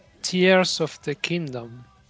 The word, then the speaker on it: Tears of the Kingdom: Tears, Tears, o, bueno, ¿cómo lo dices? Tú lo dices mejor que yo, mil veces. – Diría Tears of the Kingdom.